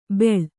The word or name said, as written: ಬೆಳ್